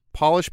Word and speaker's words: Polish: They are pronounced differently when the first letter is capitalized. Job becomes Job, polish becomes Polish, and herb becomes herb.